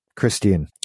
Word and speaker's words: Christian: I'm actually just looking over the IMDb of Claudia Christensen. Christian. I added extra vowels to her name, extra syllables.